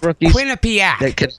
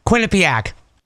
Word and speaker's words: Quinnipiac: I let you handle for the record, it's pronounced Quinnipiac. That's who Minnesota's playing for the title. It's pronounced Quinnipiac.